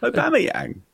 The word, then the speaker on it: Aubameyang: Aubameyang. – Aubameyang.